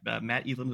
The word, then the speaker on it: Matt Eland: Yeah, that is the correct pronunciation. I was like, oh, is he gonna get it, is he not? But Matt Eland is what I go by, so it's honor to be here.